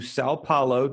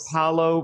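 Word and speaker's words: São Paulo: We are sitting down here with Aaron and Tiffany Anderson, missionaries to Sao Paulo. Did I say that right, Aaron? – Yes, you said that right. – Sao Paulo, Brazil.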